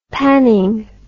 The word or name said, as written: panning